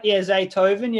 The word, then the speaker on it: Zaytoven: Yeah, Zaytoven.